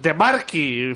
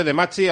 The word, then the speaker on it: De Marchi: De Marchi. – Alessandro De Marchi. – Bueno, escrito De Machi, así que yo lo pronuncio como se escribe, De Machi, ahora mismo se llama maglia.